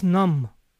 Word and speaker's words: numb: My leg is numb. Recuerden, la B de numb no se pronuncia.